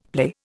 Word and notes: play: Indian

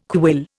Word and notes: quill: Indian

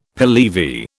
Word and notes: Pehlevi: British English

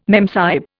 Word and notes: memsahib: Indian

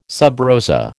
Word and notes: sub rosa: American English

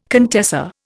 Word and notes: contessa: Indian

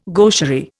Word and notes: gaucherie: Indian

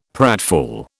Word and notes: pratfall: British English

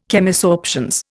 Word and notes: Chemisorptions: Indian

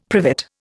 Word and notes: privet: Indian

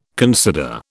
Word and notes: consider: British English

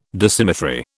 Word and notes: Dissymmetry: British English

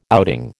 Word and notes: outing: American English

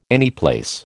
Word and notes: anyplace: American English

